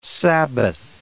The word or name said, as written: Sabbath